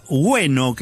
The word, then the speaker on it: Gwenno: Bueno, se trataba de la banda Bueno, que se escribe G-W-E-N-N-O.